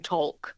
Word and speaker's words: talk: We still have the L in the spelling, don't we? But none of us would say walk and talk. None of us pronounce the L there.